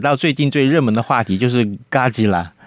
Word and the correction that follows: 哥吉拉: 哎，我我发音对吗？ – 哥吉拉，哥吉拉啊，哥吉拉。